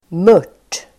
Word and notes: mört: [mör_t:]

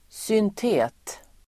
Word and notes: syntet-: [²synt'e:t-]

